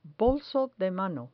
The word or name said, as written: bolso de mano